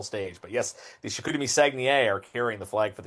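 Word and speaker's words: Saguenéens: Well, as best as my English tongue can say it, it's Sagnier.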